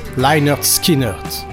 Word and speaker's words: Lynyrd Skynyrd: Lynyrd Skynyrd, pronounced Lynyrd Skynyrd.